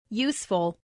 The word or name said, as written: useful